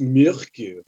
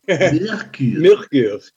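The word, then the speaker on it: Myrkur: And I think they're pronounced Mirka. I don't know. – You'll find it's pronounced Mirkur. – Mirkur.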